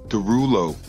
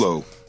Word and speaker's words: Derulo: Derulo. – Like Jason Derulo? Is that... that seriously? – Derulo. – Okay. So is it him? No, it's David Derulo. Is that really how you pronounce his name?